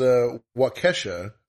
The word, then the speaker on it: Waukesha: I mean, first time offenders, like for this, Waukesha is how it's pronounced.